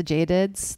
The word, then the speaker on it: Jade: Do you know that that's how you say my name in Spanish? It's Jade, but, and I think it's still pretty, but then when you say it in French, it's kind of a, aggressive. It's like Jade, Jade.